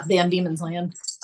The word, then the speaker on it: Van Diemen's Land: Did I say that right? – Yeah, Van Diemen's Land. – All right. I hadn't said that. I have read that, but I have not said it out loud yet. And it's demons.